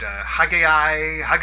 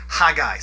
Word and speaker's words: Haggai: Because I've heard some hilarious pronunciations of his name. I've heard Haggai, probably my favorite, Haggai. It's just simply Haggai. Think of the word Hag, think of the word I, put them together, Haggai.